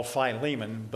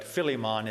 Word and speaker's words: Philemon: But Ephesians and Colossians and Philippians and what we call Philemon, but Philemon is the actual pronunciation of the name.